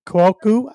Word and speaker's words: Cuoco: I botch her last name all the time, but it's Kaylee Cuoco, I believe is how you pronounce her last name.